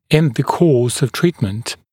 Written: in the course of treatment

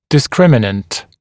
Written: discriminant

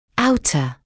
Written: outer

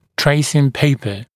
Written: tracing paper

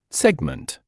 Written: segment

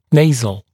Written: nasal

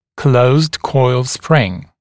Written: closed coil spring

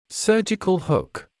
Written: surgical hook

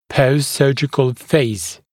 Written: post-surgical phase

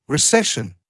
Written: recession

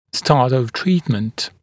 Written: start of treatment

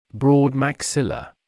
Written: broad maxilla

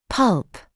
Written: pulp

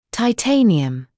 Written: titanium

